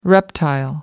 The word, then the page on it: reptile: American English